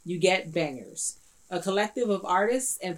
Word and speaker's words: BNGRS: So this week, the Artist Spotlight is going to be on an artist collective out of LA called Bangers. And it's spelled B-N-G-R-S.